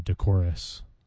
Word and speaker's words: decorous: He's decorous. Or you could say decorous. I like the emphasis on the first syllable.